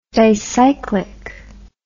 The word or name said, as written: dicyclic